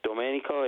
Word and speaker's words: Domenico: It's Domenico. It's Italian.